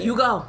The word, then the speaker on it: Yugo: You Go. – I ain't heard it. – It's spelled Y-U-G-O.